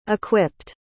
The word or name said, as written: equipped